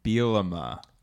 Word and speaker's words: Bielema: It's pronounced Bielema. – Bielema. – Bielema. All right, good start.